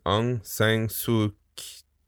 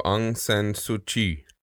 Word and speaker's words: Aung San Suu Kyi: I'm going to say her name is Aung San Suu Kyi. You said Chi? So Aung San Suu Kyi.